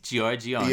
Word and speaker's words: Giorgione: Giorgione.